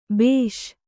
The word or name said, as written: ಭೇಷ್